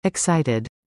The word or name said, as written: excited